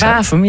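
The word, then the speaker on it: Warffum: Spreek je dat op zijn Gronings? – Wafum, ja.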